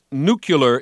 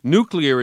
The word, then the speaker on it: nuclear: Phonetically, in fact, nuclear is pretty much the same as sicklier or wrinklier, and nobody ever gets them wrong. That nuclear pronunciation is really what linguists call a folk etymology, where the unfamiliar word nuclear is reconfigured as if it had the same suffix as words like molecular or particular.